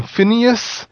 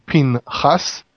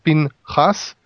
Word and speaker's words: Phinehas: Phinehas. In English, they call him Phineas. Mm-hmm. The name is really Phinehas. – Phinehas. – Two syllables, Phinehas.